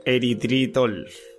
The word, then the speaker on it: eritritol: Eritritol. ¿Lo he dicho bien? Eritritol, sí.